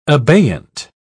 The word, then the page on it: abeyant: British English